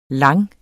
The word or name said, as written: lang